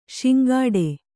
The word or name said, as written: ಸಂಕಟು